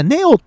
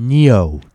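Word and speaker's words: Neo: 今の発音、ネオって言うとなんか日本語発音やけど、こう、ニオって言うと、ちょっとなんかこう、それっぽいでしょ。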